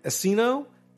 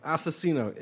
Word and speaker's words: Asesino: He also goes by Asino and Asesino.